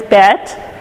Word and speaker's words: Bet: Our second letter is Bet. And it says B with a dot. Without a dot, it says V.